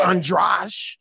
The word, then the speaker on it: Andrade: It's spelled Andrade, like Spanish, but it's Portuguese. Yeah. It's Portuguese, so it's Andrade.